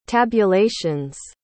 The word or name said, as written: tabulations